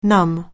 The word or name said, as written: numb